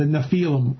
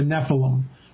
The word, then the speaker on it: Nephilim: Nephilim or the Nephilim. Or the Nephilim. There's a bunch of different pronunciations that people say. I usually say Nephilim or Nephilim. Either one works, in my opinion.